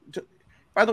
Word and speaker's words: Tobe: um, to, to, by the way, is it Toby Hooper or Tobe Hooper? I've heard it said both ways. I think it's Toby, but I could okay. be wrong.